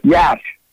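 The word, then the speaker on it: Iași: And today when I flew out of, it's called Yash. Yash, trust me, no spelling bee champ in the world would ever figure out the spelling on this or how to pronounce it. But it's Yash.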